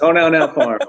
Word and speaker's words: Honaunau: The founder of, both Artisan Botanics, Mana Artisan Botanics, and also, I probably am going to say this wrong, Honaunau Farms. I think yeah, pretty close. Honaunau Farms.